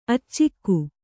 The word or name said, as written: ಅಚ್ಚುಗ